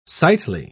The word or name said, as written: sightly